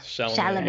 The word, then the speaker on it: Chalamet: Rebecca Hall, Timothy, how do you say it? Chalamet, is that correct? – Yeah, Chalamet. Yep.